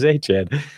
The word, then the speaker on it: Etienne: Who the hell is Travis Etienne? You missed that during the offseason. That's how he wants his name pronounced.